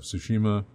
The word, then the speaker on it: Tsushima: Tsushima. – Mm-hmm. – And hey, you said it right.